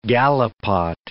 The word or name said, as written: gallipot